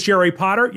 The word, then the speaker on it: Jerry Potter: My name is Jerry Potter. Yes, it rhymes with the boy wizard,